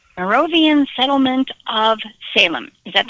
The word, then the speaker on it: Moravian: Moravian is how it's pronounced.